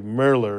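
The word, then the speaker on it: Mehler: I think it's like Merler or something like that.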